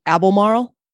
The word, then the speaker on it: Albemarle: We didn't bring this up on our last episode, but after we couldn't say the name of the Abel Marl Museum, the Abley Marley. – Yeah. Send us a recording of, she called them. She sent me a recording of their voicemail message. They said it right. I was cracking up. Oh my goodness. – Oh, okay. So it's actually pronounced the Albemarle. Oh, she's going to play it for us. – The Museum of the Albemarle.